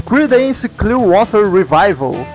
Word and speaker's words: Creedence Clearwater Revival: Enfim, eles já tocavam junto desde 59, mas foi somente em 67 que eles adotaram o nome de Creedence Clearwater Revival. Pronunciei certo, Murilo? – Perfeitamente.